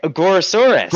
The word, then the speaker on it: Gorosaurus: Gorgosaurus? Did I say his name right? – Oh, gorgeous. Oh, I thought you were talking about Godzilla. – Oh, we're not seeing Godzilla in this. – Oh, okay. Gorosaurus. – Gorosaurus. Okay. I can never say his name right.